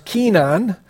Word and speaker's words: Kenan: It says, Enosh lived 90 years and begot, this is pronounced Kenan.